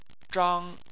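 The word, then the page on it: zhang: tone 1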